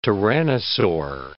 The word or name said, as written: tyrannosaur